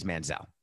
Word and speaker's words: Manziel: The pen was the weapon of choice for Johnny Manziel. Manziel. Is it Manziel or Manziel? Is it like Idina Menzel? So it is Manziel.